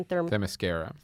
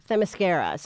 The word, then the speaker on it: Themyscira: And because they set up that she's basically lived on, how do you say the island again? – Themyscira. – Themyscira.